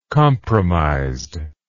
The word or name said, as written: compromised